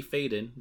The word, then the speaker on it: Faden: noting that Control protagonist Jesse Faden, did I say that right, Johnny? – Sounds right, yeah.